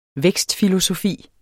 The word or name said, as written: vækstfilosofi